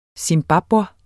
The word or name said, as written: zimbabwer